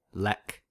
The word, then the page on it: lek: American English